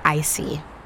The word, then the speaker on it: ICI: On a quiet street in Old City, Philadelphia, sits a bakery named Icy. That's spelled I-C-I.